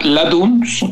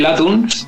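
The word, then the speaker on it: Lathums: Así que nada, vamos con la Latuns, con D, aunque se pone, se escribe con TH, pero eh, como le dice la TH en D, pues de Latuns I See Your Ghost.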